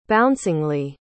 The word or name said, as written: bouncingly